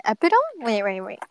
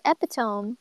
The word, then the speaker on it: epitome: I'm pronouncing it wrong. Epitome? Is it epitome? I thought it was epitome. Wait. Google, teach me how to pronounce epitome. Ah! Sorry, I almost dropped my phone. Chalate, cause. – Epitome. – Oh! Epitome. Yes.